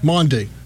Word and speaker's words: Maundy: Maundy.